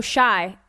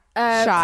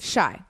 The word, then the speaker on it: Chicago: She? Chai? – No, Shy. Shy. Kourtney Kardashian has already blasted out there and she said how it's pronounced. – Shy.